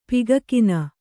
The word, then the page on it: ಪಿಗಕಿನ: Kannada; pigakina